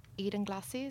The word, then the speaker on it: Edenglassie: Eden Glassie, is that how you say it? – Yeah.